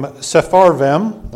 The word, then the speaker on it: Sepharvaim: And the king of Assyria brought men from Babylon, and from Cuthan, and from Ava, and from Hamath, and from Sepharvaim,